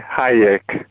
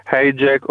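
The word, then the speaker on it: Hájek: si pronuncia lì normalmente Hayek con l'H e con la I lunga.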